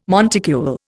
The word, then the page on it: monticule: Indian